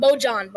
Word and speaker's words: Bojan: The people that lead the team are basically Donovan and Rudy and sometimes Bohan. Wait, did I say it wrong? Bojan. Bojan? – Yeah.